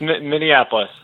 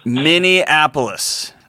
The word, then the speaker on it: Minneapolis: Minneapolis. It's not even that. It's Minnesota. The city is Minneapolis. Brother. – Yeah, it's Minneapolis. – Minneapolis. That's right. – Thanks for having my call. – Minneapolis, Minnesota.